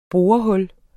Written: borehul

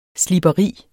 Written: sliberi

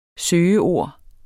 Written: søgeord

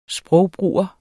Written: sprogbruger

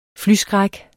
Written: flyskræk

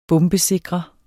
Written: BOMBESIKRE

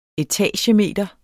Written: etagemeter

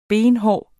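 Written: benhård

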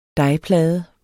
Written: dejplade

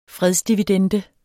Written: fredsdividende

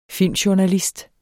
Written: filmjournalist

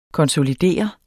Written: konsolidere

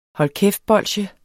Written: hold

